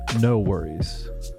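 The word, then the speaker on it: NxWorries: Then before that, we had Where I Go by No Worries, spelled NX Worries, pronounced No Worries.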